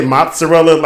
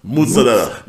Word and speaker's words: mozzarella: We're pronouncing this shit wrong, because we say mozzarella like Americans do, but apparently it's mozzarella. I didn't hear the Z when they pronounced it.